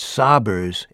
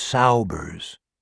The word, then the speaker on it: Saubers: Like almost everyone else, Mr. Ricker was an exception, Billy pronounced it Sobbers instead of Saubers.